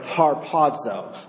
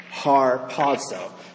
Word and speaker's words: harpazo: So Paul uses a form of the verb harpazo. I'll spell it to you, transliterate it into English. H-A-R-P-A-Z-O. Harpazo. Has the word harp in it. We get the word harpoon from it. H-A-R-P-A-Z-O.